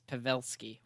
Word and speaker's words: Pavelski: So, Gerionov and Pavelski, I have to start saying it right because Dad said, oh, you can't say Pavelski anymore. – Pavelski. – It's Pavelski. Whatever.